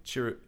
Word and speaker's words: Chirrut: Same for Chirrut. Chirrut? Is that how you say his name? – Chirrut.